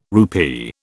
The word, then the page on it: rupee: British English